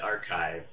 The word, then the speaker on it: arXiv: And so I'm actually a physics student, and the Archive, which is spelled ARXIV, the X is supposed to be like a Greek letter chi, is basically a place where you can publish your papers for free.